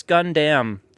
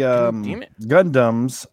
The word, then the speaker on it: Gundam: Well, next up, I want to talk about what's this Gundam thing you're talking about in your leg? – So Gundams.